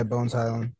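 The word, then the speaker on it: Bones Hyland: It was Bones Highland, if I'm saying that correctly. – Yeah, Bones Highland.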